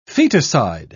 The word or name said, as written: feticide